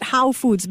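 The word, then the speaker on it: Hoow Foods: Is it How Foods? Because it's spelled H-O-O-W.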